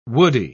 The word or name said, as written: woody